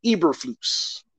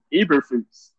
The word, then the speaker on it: Eberflus: Say it one more time. – Matt Eberflus. – Eberflus. Okay. – Flus. – Yeah, Flus.